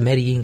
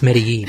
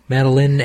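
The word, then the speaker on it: Medellín: Can't pronounce Medellin. He just can't do it. – Madeline.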